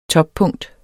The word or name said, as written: TOPPUNKTER